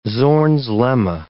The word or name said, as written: Zorn's lemma